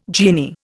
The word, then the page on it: genie: Indian